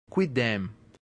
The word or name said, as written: quidem